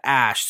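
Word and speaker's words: Æ: But they pronounce that Ash.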